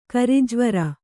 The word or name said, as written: ಕರಿಜ್ವರ